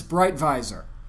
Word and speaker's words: Breitweiser: If I did it the German way, if I look at it and really study it, it's Breitweiser. – Okay. – That would be the German... Honey, there you go. That would be the German pronunciation of the name.